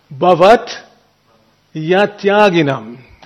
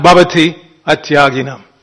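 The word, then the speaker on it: bhavaty atyaginam: So to get the meter properly, you pronounce it Bhavat Yatyaginam. The Y becomes part of the next word. Understand? So you say Bhavat Yatyaginam. Okay? Not Bhavati Atyaginam. Bhavat Yatyaginam. You run the two words together.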